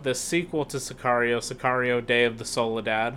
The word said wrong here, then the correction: Soldado: mm-hmm. – or Soldado. However it's said. I know I just said it wrong, but it's Soldado, I believe.